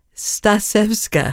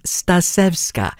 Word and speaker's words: Stasevska: Well, tonight, being Wednesday, we have Classical Californians, and it's a re-presentation of a playlist from the woman conductor, Dalia Stasevska. I always have to take a deep breath before I say her name, Stasevska.